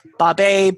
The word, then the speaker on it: Babe: Because I hate when, like, as simple as my name is spelled, it's just B-A-B-E. You wouldn't believe. Oh, Babe, baby, Bobby. It's like, motherfuckers, it's just like the pig movie.